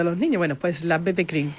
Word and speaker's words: BB Cream: BB Cream, lo he dicho bien, ¿no? – BB Cream. – BB Cream, eso es.